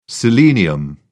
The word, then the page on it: selenium: British English